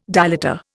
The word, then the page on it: dilator: Indian